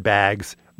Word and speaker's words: bags: These are the kind of people who pronounce the word bags, bags. Is that good? Is that a good Midwestern accent? No? Bags? – That one was better. Do the side-by-side pronunciation. I pronounce it bags, but they pronounce it... – I pronounce it bags. They pronounce it bags.